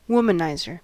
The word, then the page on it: womanizer: American English